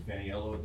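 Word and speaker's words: Feniello: Yes, I'm Peter Faniello. That's spelled F like Frank, E-N-I-E-L-L-O.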